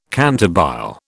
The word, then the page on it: cantabile: British English